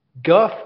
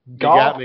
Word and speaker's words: Gough: Eleanor. Eleanor. Guff, Gew. – Goff. You got me. – Uh-oh. We stumped him. – G-O-U-G-H. – Hang on here. Oh, hang on. Listen here. Ellie Goff.